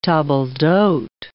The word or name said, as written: table d'hote